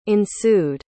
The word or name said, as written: ensued